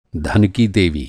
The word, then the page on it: देवी: دیوی